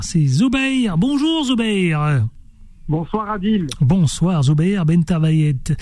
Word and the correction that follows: Ben Terdayet: Comment ça va Bien Ben C'est bien ça, je hein, j'ai pas écorché votre nom. – Ben Terdayet. – C'est ça, Ben Terdayet, absolument.